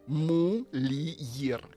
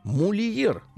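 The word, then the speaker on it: mulier: Мульер. Мульер. Ну, наверное, «р» не читается, а может, и читается. – Давайте читать. – В латыни все читал. Мульер.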